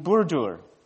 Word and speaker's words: Burdur: So I want you to try saying it with me. B-U-R-D-U-R, Burdur. Can we do it? Burdur. Okay. Don't drop the R at the end. Burdur.